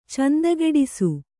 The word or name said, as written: ಚಂದಗೆಡಿಸು